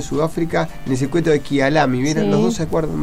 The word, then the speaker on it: Kyalami: A ver, quiero ver si lo dije bien, porque Marcelito nos pasa, ¿cómo se llama? Se llamaba el circuito de Sudáfrica, Kyalami. ¿Bien? – Sí, bien. – Sí. – Kyalami, gracias Marcelo por el aporte.